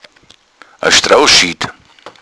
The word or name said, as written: strohschied